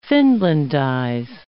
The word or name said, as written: Finlandize